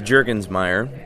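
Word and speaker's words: Jurgensmeier: So it's Jürgensmeier. It's very German, very German. We still have Jürgensmeiers in Germany that pronounce it Jürgensmeier. Okay. And so it's, you know, I spell it J-U-R, but originally it's J-U-E-R with little umlauts over the U.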